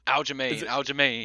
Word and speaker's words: Aljamain: Aljamain.